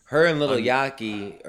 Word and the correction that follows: Yachty: or Yachi, excuse me. Yachty? How did you say that? – Lil Yachty.